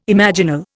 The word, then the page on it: imaginal: Indian